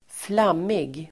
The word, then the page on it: flammig: [²fl'am:ig]